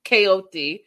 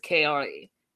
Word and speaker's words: Kaori: Well, you know, he feels like he's had this great bond with Kaori, even though he can't even say her name properly ever. Okay. Kaoti, he says. And he even had his mom saying it a couple of times until she realized, oh, her name is Kaori.